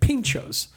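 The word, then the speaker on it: pintxos: it's spelled like P-I-N-T, like a pint, and then X-O-S. And I couldn't quite figure it out. And then before we started recording, I looked it up, and you pronounce it pinchos.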